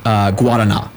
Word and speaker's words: Guaraná: It's called Guaraná. – Guaraná. – Guaraná. It's spelled with an R pronounced like a D. That's how Portuguese pronounces ours, is like D's.